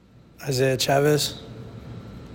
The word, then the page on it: Isaiah Chavez: eye ZAY ah CHAA vez